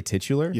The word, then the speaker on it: titular: How do you say that word? – Titular. – Is it really titular? – Yeah, it is.